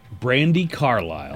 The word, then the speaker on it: Brandi Carlile: Brandi Carlile.